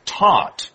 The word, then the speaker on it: taught: If you did it yesterday, you taught. Okay, so teach, taught, taught. – Right. 所以这个字很重要。Teach, 教教什么什么或者是教导。那么它的动词呢的过去式跟过去分词会变成 taught, T-A-U-G-H-T, taught. 那么 G-H 是不发音的三态 teach, taught, taught.